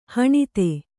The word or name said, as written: ಹಣತಿ